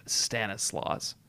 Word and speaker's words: Stanislaus: And there's some debate on whether or not Stanislaus, you pronounce the S at the end or not, but I have elected to pronounce the S at the end of Stanislaus.